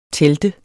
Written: telte

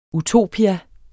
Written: utopia

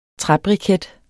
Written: træbriket

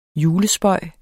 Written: julespøg

